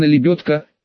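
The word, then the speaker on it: лебёдка: Слово «лебедка» ему никак не приживалось, мямлил что-то вроде «лебедки», «лелетки», но я упорно твердил, что это именно лебедка, четко, с ударением на ее.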